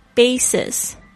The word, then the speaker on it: basis: Number three, basis, basis, B-A-S-I-S, 注意一下它的那个 A 的发音是发 A 的声音。